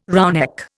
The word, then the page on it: roughneck: Indian